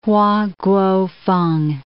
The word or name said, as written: Hua Kuo-feng